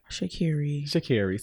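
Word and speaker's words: Sha'Carri: Shakiri.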